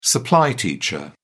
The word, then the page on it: supply teacher: British English